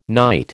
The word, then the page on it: night: American English